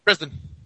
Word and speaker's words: Brisbane: That's how they say it in Australia. – Brisbane.